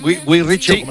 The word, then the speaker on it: Guy Ritchie: Guy Ritchie.